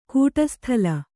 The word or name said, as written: ಕೂಟಸ್ಥಲ